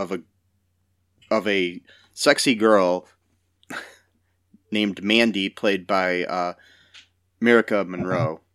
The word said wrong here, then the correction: Mircea Monroe: Mircea Monroe, sorry. I'm bad at pronouncing names.